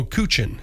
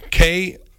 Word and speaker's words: Kuchen: So Kuchen, Kuchen, but they give me the pronunciation, and it's a German word for cake.